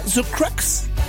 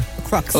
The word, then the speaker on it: The Crux: Le troisième album, je le dis bien, The Crux. – That's how you pronounce it, right? – Yes, sir. – The Crux.